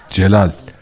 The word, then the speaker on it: Celal: Gelal var. – Gelal. – Tabii istersen kuryeyle de gönderiyoruz. – Gelal değil mi? – Gelal. Al git. – Sahibi kim Gelal'ın? Celal. – G yazılıyor, C okunuyor.